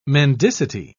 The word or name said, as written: mendicity